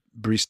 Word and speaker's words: Brice: Bruce Dolan, how do you say? – Brice Dolan. – Yeah. Brice Dolan. – Brice Dolan. – Bruce Dolan. – Brice Dolan. – We have Bruce Dolan. – Not God Bruce. Brice.